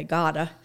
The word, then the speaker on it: Gbota: It's in a little town called, and I have never learned to pronounce it, okay, Gota. It's spelled G-B-O-T-A. And over the years,